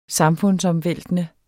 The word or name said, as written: samfundsomvæltende